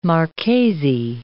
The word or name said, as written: marchesi